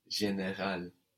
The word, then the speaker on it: général: "General" in French is "général". Général. So "ge", for example, gives us this "je" sound, like in the word for "I," "je." So "general" becomes "général."